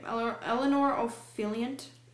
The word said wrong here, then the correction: Eleanor Oliphant: Eleanor Oliphant is completely fine.